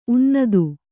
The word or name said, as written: ಉನ್ನತ